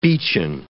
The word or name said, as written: beechen